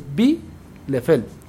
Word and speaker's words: Bielefeld: Entonces, cuando tienes una I y una E, suena I. Entonces, se dice Bielefeld.